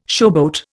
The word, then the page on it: showboat: Indian